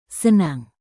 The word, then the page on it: senang: Indonesian